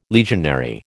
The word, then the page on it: legionary: American English